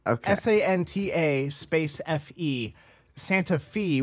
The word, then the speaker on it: Santa Fe: The thing I don't understand is when I, because it's like Shmev, when he said this before, he says S-A-N-T-A, Santa, and then it's just F-E, and he says Fee, right? – Yeah. – It's Santa Fe. – It's Santa Fe. – But yeah, it's pronounced Santa Fe.